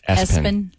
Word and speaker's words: ESPN: Somebody who's really into sports named their child ESPN. Pronounced Espen.